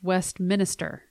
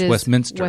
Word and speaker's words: Westminster: It's not pronounced Westminster. – No, it's Westminster. – Westminster. – There is no additional E or I between the N and the S.